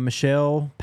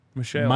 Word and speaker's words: Mishael: Washington defensive back, Michelle Powell has entered the transfer portal. – How do you spell it? – M-I-S-H-A-E-L. – Michelle. – Michael with a S, not a C.